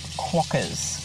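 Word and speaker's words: quokkas: And also, the little cute furry creatures you were referring to are pronounced quackers.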